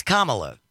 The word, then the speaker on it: Kamala: It's Kamala. – There's two ways to pronounce the name. There's one correct way for Kamala Harris. That's it.